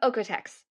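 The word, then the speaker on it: OEKO-TEX: Is it Oikotex? Ecotex? How do you say that? – Oikotex. – Oikotex. Yeah. Okay, cool.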